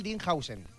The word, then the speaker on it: Dean Huijsen: Rodrigo Marcial estaba por ahí detrás, nuestro experto en fútbol internacional, Dean Huijsen.